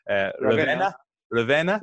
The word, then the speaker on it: Ravenna: And you were between Ravenna, to correct me if I'm saying this wrong, because I probably am. Ravenna yes.